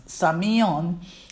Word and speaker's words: semaion: The second group, the same word sign, the Greek word samion, S-E-M-A-I-O-N, but pronounce S-A-Y-M-I-O-N, samion.